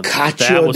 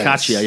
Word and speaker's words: cacio: Yeah, cacio. Yeah, I can never pronounce it. Uh, but I made that. Um, Two C's together, a generally a C, gen, gen, generally a CH.